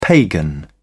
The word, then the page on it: pagan: British English